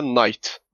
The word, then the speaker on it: knight: İngilizce'de mesela knight. Başında K var ama K sessiz.